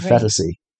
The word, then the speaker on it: Phetasy: Yeah, I'm not sure how to pronounce it. P-H-E-T-A-S-Y, right. Fetasy.